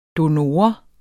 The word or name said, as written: donor